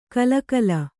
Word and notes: ಕಲಕಲ: kalakala

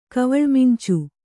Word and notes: ಕವಳ್ಮಿಂಚು: kannada

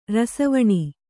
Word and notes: ರಿಸಿವೆಣ್: Kannada

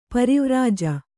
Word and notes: ಪರಿವ್ರಾಜ: pari vrāja

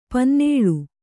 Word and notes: ಪನ್ನಾಳ: pannāḷa

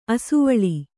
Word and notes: ಅಸವಳಿ: asavaḷi

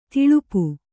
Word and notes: ಥಳಪು: Kannada

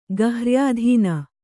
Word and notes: ಗಹ್ರ್ಯಾಧೀನ: garhyādhīna

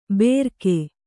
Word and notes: ಬೆರಕೆ: berake

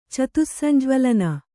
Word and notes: ಚತುಸ್ಸಂಜ್ವಲನ: kannada

